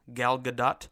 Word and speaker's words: Gal Gadot: Gal Gadot, as it's pronounced officially, Gal Gadot, she's an absolute superstar, and she has arrived with Wonder Woman.